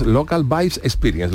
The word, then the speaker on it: Local Vibes Experience: Cuéntanos qué es esta plataforma, que es Local Vibes Experience. Lo he dicho bien, ¿no? – Lo has dicho perfectamente.